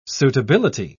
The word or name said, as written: suitability